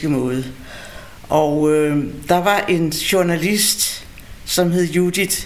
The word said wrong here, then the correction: Judita: Machasova, hvis jeg ellers udtaler det rigtigt. – Ja, Judita. – Judita, ja, det er rigtigt, undskyld.